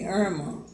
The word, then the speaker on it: Earma: So they named me Irma. Mm-hmm. But they still don't spell it like a lot of people spell Irma. A lot of people spell Irma I-R-M-A, E-R-M-A. My name spelled E-A-R-M-A.